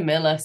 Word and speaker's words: Millar: So Mika Miller, so Millar spelt with M-I-L-L-A-R, the Scottish version, actually.